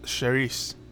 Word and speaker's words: Cerise: Cerise? – This one is actually pronounced Cerise. – Cerise, thank you.